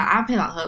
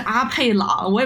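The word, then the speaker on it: apeiron: 就是，但是就我想澄清一下阿佩朗和佩朗的概念，就是阿佩朗是你永远不可能回到的一个状态。 – 有没有有没有这个词，我们都不知道。 – 真的有 A P 呃 A P E I R O N。那个教授他发的是阿佩朗，我也不知道英文怎么念。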